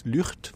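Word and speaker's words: Lugt: Lucht. Vous savez qu'en Hollande, on a des G, ça fait R, hein, donc soyons puristes. Ça s'écrit Lucht.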